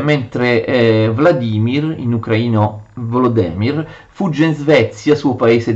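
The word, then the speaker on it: Volodymyr: Vladimir, che attenzione, gli ucraini scrivono così e eh, pronunciano Volodemir, e invece era stato dato il territorio di Novgorod, quindi tre figli, tre territori appartenenti a, uh, a Kiev.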